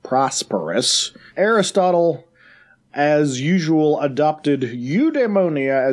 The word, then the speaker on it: eudaimonia: On today's Villainous Vocabulary, I have wrangled a word, eudaimonia. Eudaimonia. Eudaimonia. Eudaimonia. It's got the demon kind of dae syllable in it.